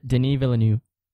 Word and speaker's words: Denis Villeneuve: You probably know how to pronounce his name better, but Denis Villeneuve, I think. – Denis Villeneuve. – There you go.